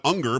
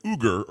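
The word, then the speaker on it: Uygur: And his last name is not Unger, but it looks like Unger, but it's really like Uger or something like that.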